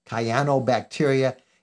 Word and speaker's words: cyanobacteria: It has to do with something called cyanobacteria, and I think I'm pronouncing it right, but cyan, like the color, cyanobacteria.